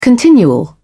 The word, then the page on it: continual: British English